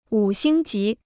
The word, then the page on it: 五星级: wǔ xīng jí